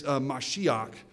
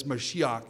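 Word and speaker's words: mashiach (משיח): And then something that has been anointed is mashiach, which is actually a word you already know, but we pronounce it Messiah. Okay, in the Hebrew, that's mashiach, and Messiah is just our sort of way of pronouncing that.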